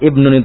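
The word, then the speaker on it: ibnun (ابن): Betul, ibnun. Iya. Ini uniknya kalau ibnun itu ketika dia mufrad hamzahnya hamzah wasal. Tapi ketika jamak hamzahnya qat'i.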